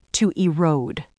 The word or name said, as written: to erode